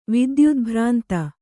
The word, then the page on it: ವಿದ್ಯುದ್ಭ್ರಾಂತ: vidyudbhrānta